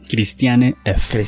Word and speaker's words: Christiane F.: Durante este periodo, Bowie explotó sus dotes como actor, pues participó, participó en un cameo para la película alemana Christine F, se dice así, Christiane F.